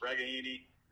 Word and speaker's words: Regaini: We found out this week the authentic Italian pronunciation is Regaini. So, we've all been saying Regaini for 5 years. It's Regaini. And because I talked to family this week, so I'm sure the East Coasters might appreciate that.